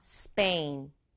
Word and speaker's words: Spain: 이 스페인은 영어로 발음할 때는 한 박자로 발음해야 돼요. Spain.